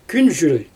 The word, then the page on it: künzhül: Xyzyl